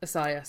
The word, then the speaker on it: Asia: Did you see the drama with Amy Schumer and Asia Jackson? Asiah, sorry. I think she pronounces it Asiah Jackson instead of Asia.